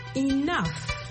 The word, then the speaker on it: enough: Ça s'appelle E-N-O-U-G-H. Et bien sûr, ça ne s'appelle pas comment ça s'entend. Ça s'entend comme E-N-O-F. Enough. Le G-H en anglais se prononce souvent comme un F. Et le O-U, en fait, ça se prononce comme un O.